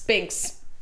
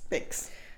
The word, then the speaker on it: Binks: And we are and this is a Thackeray Banks. Binks. Binks. – Binks. But that's how they say it in the movie.